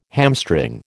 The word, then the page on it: hamstring: American English